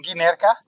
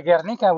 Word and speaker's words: Guernica: So I was saying it wrong. I was saying Guernica or Ganerki or whatever, or Guernica. It's actually Guernica, which is a famous painting of Picasso.